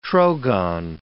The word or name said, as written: trogon